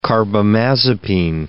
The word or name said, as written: carbamazepine